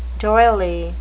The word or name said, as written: doily